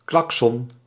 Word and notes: klaxon: Normandie; [klak.sœn]; /klak.sɔ̃/; /klak.sɔn/

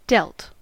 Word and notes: dealt: /ˈdɛlt/

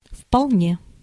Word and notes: tout à fait: France; IPA: /tu.t‿a fɛ/

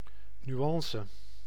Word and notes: teinte: /tɛ̃t/

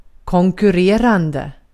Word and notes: konkurrerande: svenska

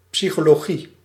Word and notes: psychologie: /psi.kɔ.lɔ.ʒi/

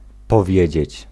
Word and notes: powiedzieć: /pɔˈvjɛd͡ʑɛt͡ɕ/

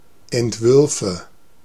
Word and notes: Entwürfe: /ɛntˈvʏʁfə/

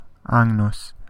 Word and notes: agneau: French

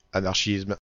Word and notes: anarchisme: /a.naʁ.ʃism/

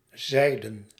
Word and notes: selyem: [sɛ.ʎɛm]; /ˈʃɛjɛm/

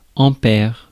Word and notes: ampère: France; [ɑ̃.pɛʁ]

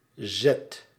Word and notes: mouvement: /muv.mɑ̃/; /mu.və.mɑ̃/

